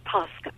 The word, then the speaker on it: Pascha: Uh, it's pronounced Pascha. Pascha. Like uh, the Paschal Lamb or the Paschal Sacrifice.